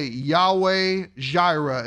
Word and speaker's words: Yahweh Jireh: Abraham called the name of that place, the Lord will provide, or we would say Yahweh Jireh is how you say it in Hebrew.